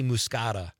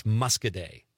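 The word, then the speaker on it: Muscoda: I always want to say Muscata, probably because I'm thinking of the wine, but I've been corrected several times on this show. It's Muscaday.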